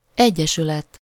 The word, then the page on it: vereniging: [vǝɾ.e.nɪ.ɣɪŋ]